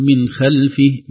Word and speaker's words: خلفه: من خلفه، من خلفه، هنا نفخم الخاء لأن الخاء من الحروف التي تفخم، حروف من حرف المستعلي يرتفع أقصى اللسان عند النطق بها.